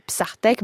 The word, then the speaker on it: bsahtek: On le retranscrit à l'aide de l'alphabet romain, hein, le nôtre, Psartek, B-S-A-H-T-E-K. Le H permet l'aspiration qui n'existe pas dans la prononciation du français, Psartek, et qu'on prononce d'ailleurs légèrement R en français.